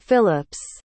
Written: Philips

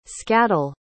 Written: scaddle

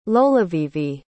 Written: lolovivi

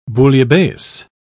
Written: bouillabaisse

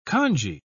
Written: kanji

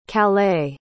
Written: calais

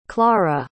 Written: Klara

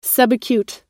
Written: subacute

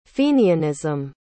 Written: fenianism

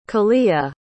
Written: kalea

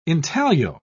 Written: intaglio

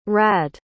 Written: radde

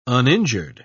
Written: uninjured

